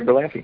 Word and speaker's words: Berlanti: Uh, is it Berlanti? Am I saying that right? Yeah, Greg Berlanti.